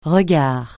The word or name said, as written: regard